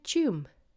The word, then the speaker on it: Tuam: What about Chum? Chum. Wouldn't you think that's spelled C H O O M? Chum. No, it's spelled T U A M.